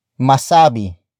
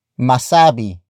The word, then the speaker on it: masabi: The first word is masabi, masabi, masabi. Masabi is spelled as M A S A B I.